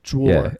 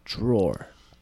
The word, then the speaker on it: drawer: I have one of the most precious people to me always corrects my words to say it right, and it's drawer.